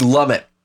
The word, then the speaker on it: Lumet: Did I pronounce the Lumiere? T? – Sidney Lumet.